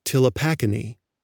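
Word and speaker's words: Ptilopachini: Recall that one sub-family we talked about is called Tilopacini. That word has a silent P, so it begins with P-T-I-L-O.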